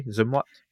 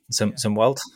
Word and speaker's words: Zumwalt: Am I pronouncing that correctly? Zumwalt? – I think so, yeah. Zumwalt, something like that.